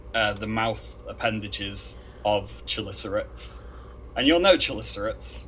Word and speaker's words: chelicerates: The chelicerates.